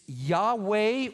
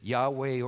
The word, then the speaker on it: Yahweh: It's YHWH or YHVH. It could be pronounced either way, Yahweh or Jehovah, one of those.